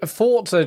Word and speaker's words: Forza: If I would say Forza just feels like I'm being a pretentious twat. Like, no, it's just pronouncing this correctly.